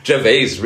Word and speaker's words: Gervais: Wir haben ihn jetzt beide falsch ausgesprochen. Gervais, Ricky Gervais.